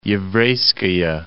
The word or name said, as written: Yevreyskaya